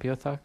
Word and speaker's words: Beothuk: Did I pronounce it right, Beothuk? – I think that's how you pronounce it if you don't want people to giggle.